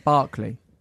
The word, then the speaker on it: Berkeley: Born in Illinois, the only child of refugees, Ruby studied psychology at Berkeley. Did I say that right? Berkeley. Yeah. Berkeley.